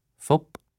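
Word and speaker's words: Phúc: Phop. The letter C at the end of my name isn't even pronounced like a C, thanks to archaic orthography and sound changes in the language. It's a P sound.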